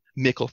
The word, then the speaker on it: Mikkel: is it Mikkel or Michael? – B. Rasmussen? – Mikkel, thank you.